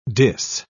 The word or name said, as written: diss